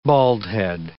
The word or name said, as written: baldhead